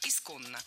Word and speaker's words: исконно: Второе похожее слово – «исконно». Здесь уже нужны две «н», а ударение ставим на второй слог – «исконно».